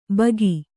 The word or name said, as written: ಭೋಕು